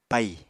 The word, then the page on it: paì: Apatani